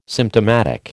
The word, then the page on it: symptomatic: American English